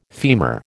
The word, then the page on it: femur: American English